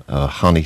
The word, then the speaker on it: Jan: Hani Schaffer, I think is the correct pronunciation in Swedish.